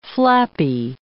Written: flappy

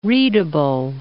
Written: readable

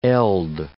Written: eld

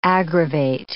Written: aggravate

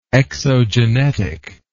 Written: exogenetic

